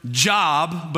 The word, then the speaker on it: Job: You're looking for a book in the Old Testament that looks like it's spelled like the word job, but it's actually pronounced Job.